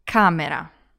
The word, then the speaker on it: camera: And remember to put the stress on the first part, so it's camera.